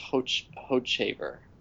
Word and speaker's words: Hochevar: I always pronounce it Hochevar. Hochevar. – It's one of those. – But then lately I heard it pronounced Hochevar with its hard CH. Hochevar.